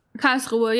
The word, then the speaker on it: Karlsruhe: Did I butcher that name? Karlsruhe or Karlsruhe, yeah.